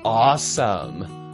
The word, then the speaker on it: awesome: 第三个我们要学习的是 awesome。 awesome, A-W-E-S-O-M-E, A-W-E-S-O-M-E。值得注意的是 awesome 这个字的发音不念作 awesome, 它念作 awesome。